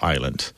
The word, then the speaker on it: island: The S is not pronounced in French. Therefore, we don't pronounce it in this word island. So I'm going to recommend a book. It's by Erica Okrent, O-K-R-E-N-T.